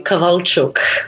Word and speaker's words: Kowalczuk: It doesn't look like it should sound like this, but it is technically Kowalczuk.